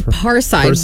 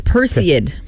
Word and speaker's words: Perseid: I thought it was Parseid, but it's Perseid.